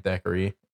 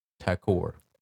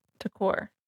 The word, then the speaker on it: Thakur: The winners were David and Thackeray. And I wrote down the pr- pronunciation of his name is Takor. Takor.